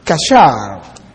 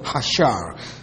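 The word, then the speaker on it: Kasher (חָסֵר): Now, this is because the verb used here is the Hebrew word kasher. Kasher. C-H-A-S-E-R. Kasher. Some pronounce it hashar. It's still a rough breathing, as they say.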